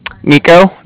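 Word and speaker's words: Niko: Nico. That's N-I-K-O. And I'm calling from Cardiff, and that's spelled C-A-R-D-I-F-F, as in Frank Frank.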